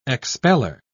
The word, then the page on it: expeller: British English